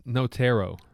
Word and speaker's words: Notaro: All right, well, before we get into Tig Notaro, how do you say it? Notaro? – It's Notaro. – Notaro? – Yeah, it's Tig Notaro.